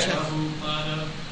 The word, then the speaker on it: Prabhupada: I think there is, isn't there, there's no long A there? – Prabhupada. – Oh, Prabhupada. – There's a long A. – Oh, at the end, at the end. Oh, yeah, Prabhupada. That was short.